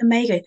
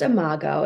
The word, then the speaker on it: imago: And my understanding is that you also specialize in, is it omega therapy? that you... – Yeah, it's pronounced imago. It's spelled I-M-A-G-O for anyone who wants to Google it.